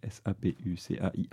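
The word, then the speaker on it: Sapucaia: On a modifié l'orthographe du nom pour qu'il soit bien prononcé en France quand même, parce que normalement c'est Sapukaya. – D'accord. – C'est-à-dire ça pue, Kaya, donc c'est pas terrible quoi.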